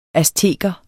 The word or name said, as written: azteker